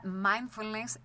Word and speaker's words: mindfulness: Técnica mindfulness, ¿es así? ¿Lo dije bien? – Así es, muy bien.